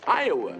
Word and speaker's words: Iowa: You are in Iowa. – Iowa. Well, at least now I know how to pronounce it. I always thought you folks preferred Iowa. – We do. – Well, he just said Iowa.